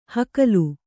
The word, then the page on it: ಹಕಲು: Kannada